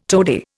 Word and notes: toady: Indian

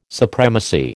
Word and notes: supremacy: American English

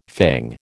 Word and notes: fang: American English

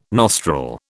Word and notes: nostril: British English

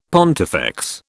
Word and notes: pontifex: British English

